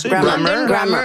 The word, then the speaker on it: Grammar: En l'écoute de Bonne nuit, chérie et Soleil secret, j'ai pensé à un groupe qui s'appelle London Grammar. Je le prononce bien? – Grammar. Oui.